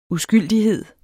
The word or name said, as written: uskyldighed